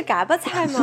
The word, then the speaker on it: 嘎巴菜: 本地应该怎么念啊？ – 锅巴菜，嗯，锅巴菜，嘎巴不应该是嘎巴菜吗？ – 就是嘎巴菜。